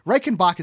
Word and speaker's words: Reichenbach: Reichenbach is how you say it, right? I'm pronouncing that right. – That's how I say it.